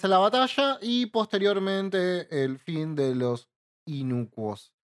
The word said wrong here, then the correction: Inocuos: o como se pronuncia. – Inocuos.